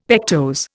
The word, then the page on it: pectose: Indian